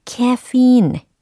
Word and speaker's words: caffeine: I have 다음에 the caffeine 예, 카페인 이게 아니고 카페인도 아닌 caffeine 이렇게 발음해 주셔야 되는 거였죠.